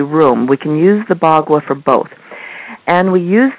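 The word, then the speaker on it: Bagua: I pronounce it Baghua, and Baghua. The meaning of it is 8-sided. Ba is 8, and gua is sides. Now, there's so many different ways of pronouncing it, so I just wanted to let you know that that's how I will be pronouncing it.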